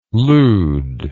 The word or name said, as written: leud